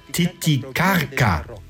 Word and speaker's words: Titicaca: Richtig ausgesprochen, Titicaca. Dieser heilige Ort der Inkas gab dem ganzen See seinen Namen. Die spanischen Eroberer konnten es aber nicht richtig aussprechen und so wurde aus Titicaca Titicaca. Aber korrekt müsste man sagen Titicaca.